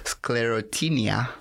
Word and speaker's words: Sclerotinia: Sclerotinia. – Sclerotinia.